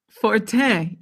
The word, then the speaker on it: Fortin: Fortin, Elon. You're Canadian. You learned French in school. – Oh, it's like Jacques Martin.